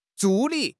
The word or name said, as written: 助理